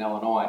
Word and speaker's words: Illinois: Those of you who are closer to that area, Indiana and Illinois.